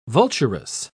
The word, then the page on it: vulturous: British English